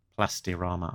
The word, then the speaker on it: Plastirama: She was an exclusive figure available in Argentina from Plaz Plastorama. – Plastirama. I was going to pronounce, that wrongly.